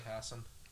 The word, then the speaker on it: Hassan: I'd say Hassan. – Yeah. – How's it spelled? – H-A-S-S-A-N.